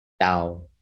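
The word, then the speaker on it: Dao: So that would be like the Western pronunciation. But actually, it's a more of a D in pronunciation than it is a T. So it's right. – Tao.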